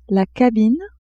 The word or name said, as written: cabine